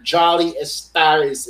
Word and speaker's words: Jolly Estaris: Mr. Jolly Estaris is on the podcast.